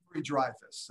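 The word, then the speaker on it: Dreifuss: His eldest son, Harrison, who has Emery diffusus. Did I say that right? – Dreyfus. That's close. It's hard.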